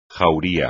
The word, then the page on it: jauría: Spanish